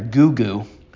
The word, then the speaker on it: Gugu: depending on who's pronouncing it, Gyges, G-Y-G-E-S, or this one's even better, Gugu, G-U-G-U.